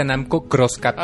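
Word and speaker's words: Namco × Capcom: Mira, este juego, de hecho se pronuncia Namco Cross ah, Capcom.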